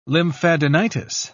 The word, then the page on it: lymphadenitis: British English